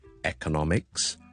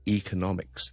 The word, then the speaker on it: economics: 有好多字都系类似咁样，譬如经济学啦，我哋 economics， or we can say economics，亦都得，两个都系广为接受发音嚟嘅。